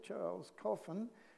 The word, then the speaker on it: Charles Coffin: The next hymn, number 13, was composed by Charles Coffin, so the footnotes say, Charles Coffin, pronounced Charles Coffin. I don't know how good your French is, but he lived from 1676 to 1749.